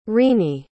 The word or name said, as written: rini